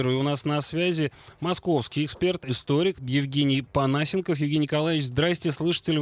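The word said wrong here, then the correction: Понасенков: Здравствуйте, слышу, что неправильное ударение. – Панасенков.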